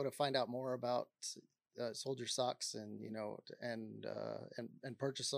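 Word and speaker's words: Soledier Socks: It's spelled S-O-L-E, like the sole of your foot, D-I-E-R-S-O-C-K-S. So it's a play on words.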